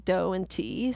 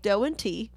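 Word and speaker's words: Do and Ti: D-O and T-I, but it's pronounced Doe and T. So if you hear me say that again, that's like another thing that they call them. So Doe and T.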